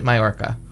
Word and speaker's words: Mallorca: It's spelled it with two good. L's, so you may not have ever known that you pronounced it Mallorca.